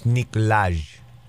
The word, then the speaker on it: Nikolaj: Did I come pretty close to the way he pronounces his name? – I think it's Nikolai. – Nikolai Coster-Waldau. – I'm just guessing off Brooklyn Nine-Nine. It's a Nikolai. – He played Jamie Lannister? – No, it's Nikolaj.